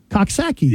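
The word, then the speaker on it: Coxsackie: It's C-O-X-S-A-C-K-I-E. – Cooksackie.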